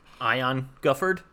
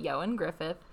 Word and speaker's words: Ioan Gruffudd: Ion Gufford. his name is yeah, you pronounce it Ioan Gruffudd, and I know because I was obsessed with him. It's Welsh.